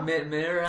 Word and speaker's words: Mera: M-E-R-A. – Oh, right. Okay. – Is that how you say it? Mira? – Mira.